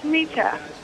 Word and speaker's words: Shemitah: So my other question was for the Shemitah. – Yeah. – Is that how you say it? – Mm-hmm.